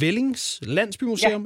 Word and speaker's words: Vellings Landsbymuseum: På tredjepladsen over steder i Danmark, hvor man altså kan få en på opleveren, der fremhæver I, jeg tror, det udtales Vellings Landsbymuseum, er det sådan? – Ja. Ja, det er korrekt.